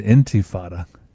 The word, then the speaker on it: intifada: I think it's pronounced the intifada. I think you're saying it. – Did I say it correctly? – I think you're saying infantata. – Oh, I did. Intifada, yes. Intifada.